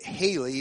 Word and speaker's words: Halley: Edmund, I think we pronounce it Halley, even though it's spelled H-A-L-L-E-Y.